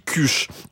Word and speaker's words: kush: Alors, ça s'écrit K-U-S-H. Moi, je dis cuche.